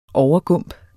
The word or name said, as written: overgump